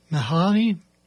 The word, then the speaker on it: Mihali: We started off with Mihaly. Maybe that's how it is. M-I-H-A-L-I. That's how it's pronounced, if you want to look it up.